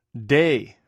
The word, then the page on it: dei: greek